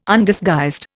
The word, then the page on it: Undisguised: Indian